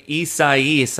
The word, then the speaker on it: Isaias: Hurricane Isaias, I believe, is how they wanted it to be pronounced.